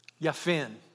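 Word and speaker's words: Japheth: Yaphin, thank you. It's an E. I even wrote that down. It's got a long E.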